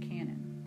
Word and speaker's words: canon: These three sections make up the entire Old Testament, the New Testament canon. Canon, C-A-N-O-N, that's how it's spelled.